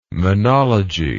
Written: monology

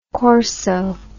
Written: corso

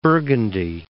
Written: Burgundy

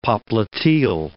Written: popliteal

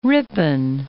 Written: Ripon Falls

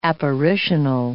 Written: apparitional

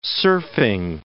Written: surfing

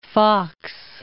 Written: Foxe

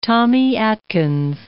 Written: Tommy Atkins

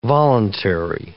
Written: voluntary

